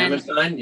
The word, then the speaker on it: Hammerstein: Hammerstein, Oscar Hammerstein. – Hammerstein, yeah, Oscar Hammerstein, yeah.